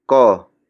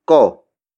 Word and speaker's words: ko: Kotse ko yan. The first word is kotse. Kotse. Kotse. Kotse is spelled as K-O-T-S-E. It means car. It is borrowed from Spanish. The next word is ko.